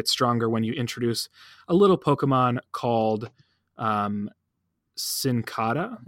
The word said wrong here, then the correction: Nincada: Am I saying that right? Nincada. So let me say that again.